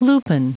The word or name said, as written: lupin